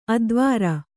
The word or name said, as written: ಅಧ್ವರಿ